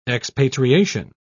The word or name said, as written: expatriation